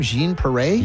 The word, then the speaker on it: Jean Paré: Is that how you say her name? Jean Paré? – Yeah.